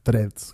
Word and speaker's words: Threads: Lo primero es lo que está de moda, el nuevo o la nueva red social Threads, que sí, así se pronuncia, Threads,